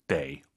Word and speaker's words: 得: 59페이지 데이 어떨 때는 더. 사실은 발음이 또 있어요. 근데 오늘은 두 개만 해보시죠. 아무리 강조해도 지나치지 않습니다. 수러 즉 동사나 형용사 앞에 있으면 더가 아니라 데이입니다.